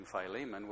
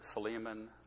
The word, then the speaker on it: Philemon: And by the way, there are different ways of pronouncing that. I think Joe was using Philemon, which was perfectly acceptable. Many people pronounce it Philemon, others Philemon, depending on where you want to stress the eta, the little e in the middle.